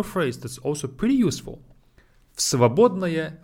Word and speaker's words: в свободное: В свободное. But we read it as one word. В свободное. And в becomes ф. В свободное. В свободное. For ease of our pronunciation.